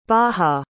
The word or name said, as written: baha